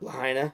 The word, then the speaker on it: Lahaina: You pronounce it? Lahaina? Lahaina? – Lahaina.